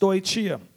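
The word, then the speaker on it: stoicheia: And there's so I found out it's a Greek word um, which is pronounced stoichia, and stoichia means uh, means effectively it's a it's a it's a level or, of existence, amen.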